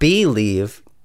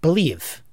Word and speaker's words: Bleav: It's spelled B-L-E-A-V. – Believe. – I believe in making it sound the way it's spelled, and it's B-L-E-A-V. But I know that they must be thinking they want it to sound like a little like believe. – A lot like believe.